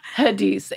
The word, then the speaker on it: Hadis: N-U-T-R-I. – I think your name. – Hadis, H-A-D-I-S.